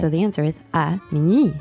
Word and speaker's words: minuit: À minuit. – So let's practice the pronunciation of the word for midnight. – Minuit.